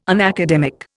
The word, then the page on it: unacademic: Indian